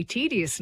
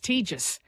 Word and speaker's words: tedious: But I don't know, a word like tedious, that we say tedious now, that was once pronounced tejus. And that was the recommended pronunciation.